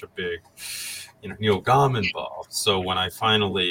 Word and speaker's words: Gaiman: I've just heard Neil Gaiman all my life, you know, like I worked, when I worked in publishing and book retail, that's how the publishers pronounced it.